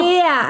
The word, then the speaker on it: ber: Und zwar, der Stadtname kommt ursprünglich aus dem Slawischen und besteht aus den beiden Wörtern Sumpf, das heißt Bär, und das Tier. – Nein. – So hast du es gerade ausgesprochen. – Bär, also B-E-R, wie Berlin.